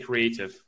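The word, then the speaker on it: Kreativ: So the new IKEA is called IKEA Creative or Creative. That's the Swedish way to pronounce it.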